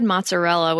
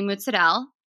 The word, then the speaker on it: mozzarella: I always said mozzarella, which is horrible. I'm embarrassed. – That's okay. It's never too late to learn how to say mozzarella.